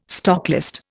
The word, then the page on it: stocklist: Indian